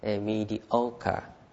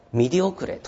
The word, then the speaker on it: mediocre: mediocre。はい。で、これ読み方気をつけてください。あの発音が不正確だと二次試験絶対合格できないです。ね。これ例えばミディオクレとか読んだら何言ってるかを分かんないんですよね。